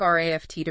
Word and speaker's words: RAFT: Now, you probably remember we set off from that dangerous shore, as we've called it, on our basic RAFT to freedom. And just a reminder, that's pronounced raft, like the boat.